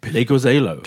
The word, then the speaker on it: Pleguezuelo: Pleguezuelo. – Pleguezuelo. – Pleguezuelo.